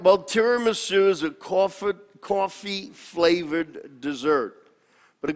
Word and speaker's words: tiramisu: So the title of my sermon is Tiramisu. In Italian, this would be correctly pronounced tiramisu. We Americanize it and we call it tiramisu or tiramisu.